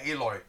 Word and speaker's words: Eloi: Well, the E-L-O-I, how do you spell that? Elo? – Oh, Eli? Yeah, Eloy. Yeah, Eloys.